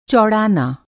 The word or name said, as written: चौड़ाना